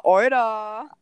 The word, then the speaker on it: Oida: Ah, Euda. – Euda. Schreibt man auch äh, O-I-D-A.